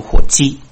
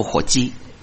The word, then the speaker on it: 活祭: 我的国语不标准，有时候读得快，把活祭念成火鸡，将身体献上当做活祭。 OK，好，不是的，活祭。